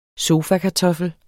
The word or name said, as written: sofakartoffel